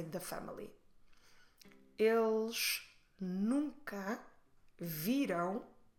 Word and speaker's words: viram: When you say viram, make sure you stress the second to last syllable, vi, but then close your lips in the end to make the nasal um.